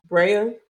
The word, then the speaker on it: Tsireya: Rhea? – Yeah, she goes by Rhea, but it's spelled T-S-I-R-E-Y-A.